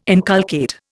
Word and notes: inculcate: Indian

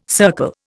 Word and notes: circle: Indian

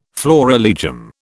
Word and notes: florilegium: British English

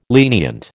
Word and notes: lenient: American English

